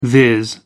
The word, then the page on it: viz: American English